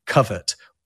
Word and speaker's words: covert: But in the 1960s, for whatever reason, an American pronunciation of covert appears, probably influenced by overt, right?